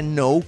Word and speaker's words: know: Y si ustedes recuerdan bien, ese know comienza con K, pero no se pronuncia.